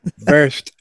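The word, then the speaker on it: Wurst: Excuse me, pronounced verst.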